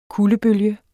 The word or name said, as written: kuldebølge